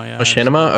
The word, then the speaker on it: Machinima: How do you actually pronounce this? Is it Machinima? – Machinima. – Okay, I never actually knew that.